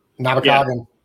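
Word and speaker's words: Namekagon: So yeah, so this weekend I'm headed up to the, I always say it wrong because I've not been there enough. Namakagan people. – Namakagan. – Right. Namakagan Barrens.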